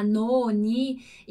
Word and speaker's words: non: Então, quando eu digo non, a minha língua ela encosta lá no céu da boca, como se eu fosse falar uma sílaba, né? Ou seja, com alguma outra vogal. Então, na, no, ni. E aí, só que aí eu não falo essa vogal. Então, fica non. Dillo chiaro? Non. Parece, pode até soar um pouco como se tivesse ali um A depois, né? Tipo nona, mas não é, a gente não fala o A, ok?